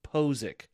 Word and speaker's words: Ethan Posick: Okay, so his last name's spelled P-O-C-I-C, which a lot of people pronounce it Pochich. It's actually, for phonetic spelling, is P-O-H-Z-I-K, Ethan Posick.